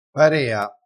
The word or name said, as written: parea